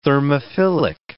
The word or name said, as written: thermophilic